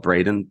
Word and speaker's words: Braden: So there is a lot more in the world that I don't feel like I'm like some outcast because I was called Braden, but people spell it differently. Like throughout my whole life, people just say Brandon.